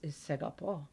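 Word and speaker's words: Segapo (σ'αγαπώ): Also important for people who are maybe dating a Greek or whatever is segapo. – Oh, segapo. – Am I? Did I mispronounce it? – No, you said it right.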